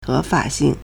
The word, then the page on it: 合法性: héfǎxìng